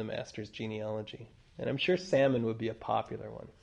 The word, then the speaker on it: Salmon: And, of course, the source for her marrying Salmon, which is pronounced Salmon. Salmon. Like Salmon. Salmon. Salmon. Salmon. But it looks like it's pronounced salmon.